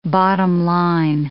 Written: bottom-line

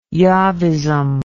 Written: Jahvism

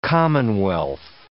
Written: commonwealth

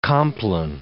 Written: compline

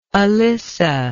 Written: alissa

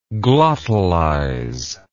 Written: glottalize

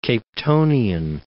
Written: Capetonian

